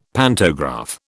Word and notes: pantograph: British English

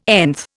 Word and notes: nth: Indian

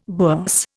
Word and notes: bourse: Indian

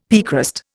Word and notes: piecrust: Indian

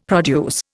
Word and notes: produce: Indian

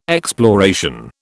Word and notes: exploration: British English

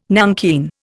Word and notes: nankeen: Indian

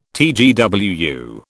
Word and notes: TGWU: British English

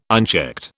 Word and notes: unchecked: British English

